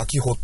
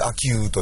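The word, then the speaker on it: 秋保: 普通で読めば秋穂って言うんですけど、はい、それで秋雨と読むんですけども。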